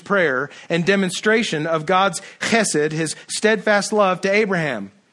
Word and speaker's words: chesed: That word steadfast, the word that is translated steadfast love is the Hebrew word chesed. And if you don't spit a little bit when you say it, you're not saying it right.